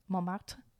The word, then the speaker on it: Montmartre: Like, Montmartre. How do you say it? – Montmartre.